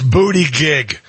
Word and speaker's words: Buttigieg: It's booty gig. Pronounce it properly.